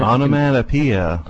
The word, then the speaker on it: onomatopoeia: Did I pronounce it right? – Onomatopoeia. – Onomatopoeia. I could never get it right.